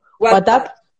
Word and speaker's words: WhatsApp: what, no sé si pronuncié bien, WhatsApp.